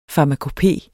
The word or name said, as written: farmakopé